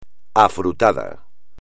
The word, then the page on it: afrutado: espagnol